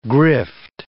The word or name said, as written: grift